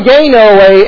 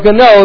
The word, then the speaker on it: Gano: John Gano, or Gano, if you'd like to pronounce it that way, prominent figure in the Revolutionary War, Baptist pastor, was a personal friend of George Washington.